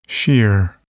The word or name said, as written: shear